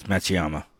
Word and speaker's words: Matsuyama: It's Matsuyama.